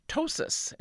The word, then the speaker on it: ptosis: And another P word, again, sounds like a T word, ptosis. It's spelled P-T-O-S-I-S.